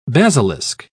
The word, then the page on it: basilisk: British English